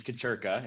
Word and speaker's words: Kuchirka: Like always, just say it like a sneeze, Kuchirka, and you did it just fine, Brian.